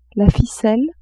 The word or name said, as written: ficelle